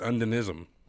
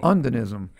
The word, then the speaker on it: Undinism: Undine is like just like he said, and then it's Undinism. So Undinism.